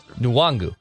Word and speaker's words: Nwangu: It's small N-E-W, capital N-A-N. – It is Nwangu. – G-O. Nwangu. – I know Chris Longer said the N was silent. I don't think he was correct in that assessment. And I know I shouldn't be the one who's critiquing pronunciations and spelling and reading. – Especially professional TV sports. – It's Nwangu. – Yes, according to the pronunciation guide, you're correct. Chris Long is wrong.